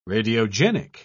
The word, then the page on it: radiogenic: British English